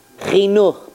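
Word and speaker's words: Chinuch (חינוך): How do you say Jewish education in Hebrew? Chinuch, because you dedicate a person to education, chinuch, okay?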